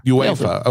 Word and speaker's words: UEFA: UEFA.